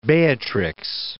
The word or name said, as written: Beatrix